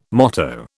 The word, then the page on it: motto: British English